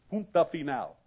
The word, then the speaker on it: punto final: Punto final. Did I say that right? Thank you.